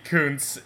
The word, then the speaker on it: Kuntz: Or, as a dumb American would pronounce it, it's K-U-N-T-Z.